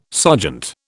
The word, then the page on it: sergeant: British English